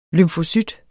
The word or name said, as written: lymfocyt